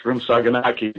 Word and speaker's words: shrimp saganaki: And that shrimp saganaki is first on the list. – Shrimp saganaki. Did I say that correctly? – Yep. – Good. – You sure did.